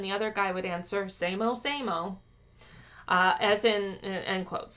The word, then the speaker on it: SAMO: It looks like SAMO almost, but kinda, it's pronounced SAMO.